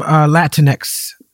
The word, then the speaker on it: Latinx: With the term Latinx I didn't say that right did I